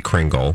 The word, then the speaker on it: Kringle: We say Kringle because in Wisconsin it's Kringle. – But you can pronounce it Kringla, right? – Because I'm assuming the Danish way is like Kringla.